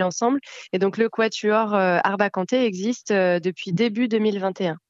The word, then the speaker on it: Arbacanté: Et puis, on a laissé quand même un accent aigu sur le E de Kanté, parce que on avait envie que les gens le prononcent Kanté et pas Kant.